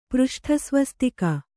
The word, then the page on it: ಪೃಷ್ಠಸ್ವಸ್ತಿಕ: přṣṭha svastika